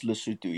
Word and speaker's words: Lesotho: It's pronounced Lesotho. Yes.